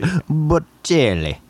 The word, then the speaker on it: Bocelli: I had to look up the pronunciation on YouTube, and it was Bocelli.